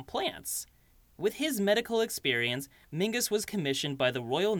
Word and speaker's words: Menzies: That name, Mingus, despite being pronounced Mingus, is actually spelled M-E-N-Z-I-E-S.